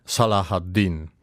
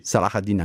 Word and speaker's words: Salah ad-Din: al principe Carlo e al feroce Saladino. Lei mi insegna che studia arabo, la pronuncia è un'altra. – Salah ad-Din. Sì. – Salah ad-Din anche a lei.